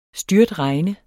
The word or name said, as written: styrtregn